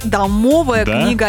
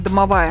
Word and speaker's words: домовая книга: А ты знал, что правильно говорить домовая книга, а не домовая? – А я домовой, да домовой.